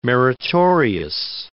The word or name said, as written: meritorious